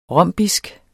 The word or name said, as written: rombisk